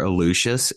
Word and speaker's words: Aluxes: If you just search for Aluxes, it's spelled A-L-U-X-E-S. We use the Spanish pronunciation for the word.